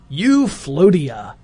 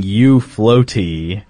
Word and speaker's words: Euflotia: So it's spelled Euplotea. – And it's pronounced Euflotia. – Oh, I was thinking Euflotee. – Nope. – That's what I got, too. – Euflotia. It is actually pronounced Euflotia.